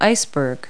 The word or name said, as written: iceberg